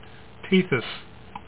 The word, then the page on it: Tethys: TEE this